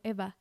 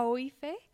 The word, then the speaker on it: Aoife: dice Aoife, uh-huh. algo así. Y creo que al final, hasta el final... Dice el otro personaje de que, ay, se pronuncia como Eva. Uh-huh. Y yo, ¿cómo pasas a Oife? – Sí.